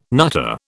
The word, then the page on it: nutter: British English